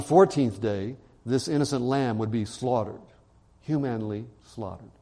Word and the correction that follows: humanely: humanely slaughtered is the correct pronunciation of that word.